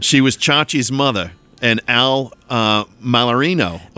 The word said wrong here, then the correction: Molinaro: of course. – Molinaro. – Oh, is it Molinaro? – I think so. – I've been pronouncing it wrong since the 70s.